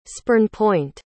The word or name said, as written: spurnpoint